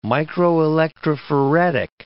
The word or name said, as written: microelectrophoretic